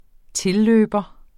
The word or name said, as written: tilløber